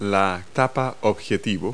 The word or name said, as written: lens cap